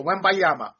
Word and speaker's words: Wembanyama: And, look, you know, the thing about Victor, and let me say this, Wembanyama. I always like to pronounce it, Wembanyama.